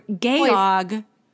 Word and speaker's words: Georg: It's the German Georg for George, but with a British accent, which is Georg.